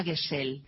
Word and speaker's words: Gesell: Gesell es un apellido de origen alemán. Quiere decir compañero y se pronuncia Gesell.